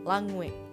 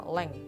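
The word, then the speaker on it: langue: Oke, lanjut ke yang ketiga ya, namanya langwe atau dibacanya leng.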